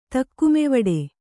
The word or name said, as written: ತಕ್ಕುಮೆವಡೆ